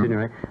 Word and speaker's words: Signoret: you know, Larry, one thing I've always noticed, though, you get the most exciting leading ladies for your pictures, like Simone Signoret. That's the way you pronounce it, mm -hmm. Signoret.